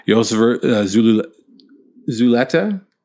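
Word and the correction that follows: Yosver Zulueta: And that's not close, is it? – Zulu. They call him. – Yasver Zulueta. – Yasver Zulueta. Oh, that's good.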